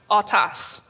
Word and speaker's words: autos: The word same in Greek is pronounced autos.